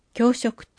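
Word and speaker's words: 共食: 教食、共に食べると書いて、教食と読みます。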